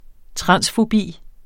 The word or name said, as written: transfobi